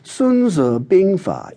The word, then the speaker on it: Sun Tzu Bingfa: Rather, Sun Tzu Bingfa, its Chinese title, which I'm pronouncing in modern Mandarin, is more accurately translated as Sun, a surname, Tzu, the masters, Bing, military, and Fa, method.